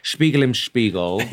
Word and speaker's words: Spiegel im Spiegel: So I have chosen a song that I love, a piece of music, Spiegel im Spiegel. – Yeah. – Classic. I think that's how you pronounce it. – Yeah, it is, yeah.